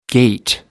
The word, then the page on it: gate: American English